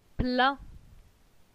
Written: plein

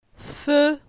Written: Fe

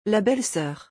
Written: La belle soeur

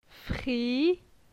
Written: Fri

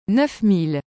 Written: 9000

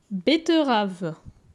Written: Betterave

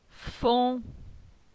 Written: Fon

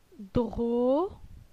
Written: Dro

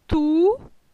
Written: Tou

